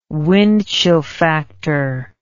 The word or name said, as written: wind chill factor